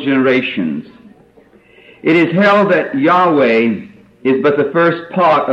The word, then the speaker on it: Yahweh: The name of God is pronounced Yahweh in Hebrew.